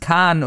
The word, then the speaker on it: Caan: James Caan oder James Chan oder James... John. – Die erste. – Gut. – Die erste Option, ja.